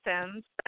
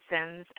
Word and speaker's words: Sims: Okay, well, Shataya Sims, let me spell it, S-H-A-T as in Tom, A-Y-A, last name Sims, S-I, M as in Mary, M as in Mary, S.